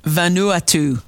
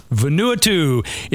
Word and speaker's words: Vanuatu: Vanuatu. – Vanuatu.